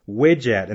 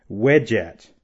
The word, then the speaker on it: wedjat: The first word is widget, and that word is spelled W-E-D-J-A-T, widget.